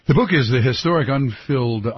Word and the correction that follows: Unfulfilled: I haven't said that right once. The Historic Unfulfilled Promise by Howard Zinn.